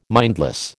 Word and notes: mindless: American English

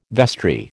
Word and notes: vestry: American English

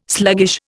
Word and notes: sluggish: Indian

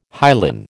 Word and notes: hyalin: American English

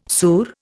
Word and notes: surra: Indian

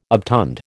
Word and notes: obtund: American English

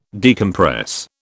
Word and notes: decompress: British English

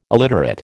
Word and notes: illiterate: American English

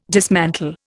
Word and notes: dismantle: Indian

